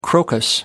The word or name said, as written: crocus